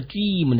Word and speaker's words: dream: 哎，在发这个 dream 的时候呢，还是要注意发音的要点，把牙齿对齐，发一二三的一。一 dream.